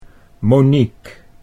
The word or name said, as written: Monique